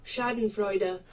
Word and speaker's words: Schadenfreude: Schadenfreude. – Schadenfreude.